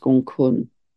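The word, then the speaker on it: Gonggong: And that also links, I'm going to talk about another dwarf planet which links right now, which is Gong Kun, spelt Gong Gong, but pronounced Gong Kun.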